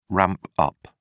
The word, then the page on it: ramp up: British English